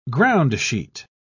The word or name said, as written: groundsheet